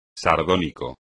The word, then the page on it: sardónico: espagnol